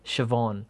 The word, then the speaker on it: Siobhan: but it is a traditional Irish name, and it is pronounced Siobhan.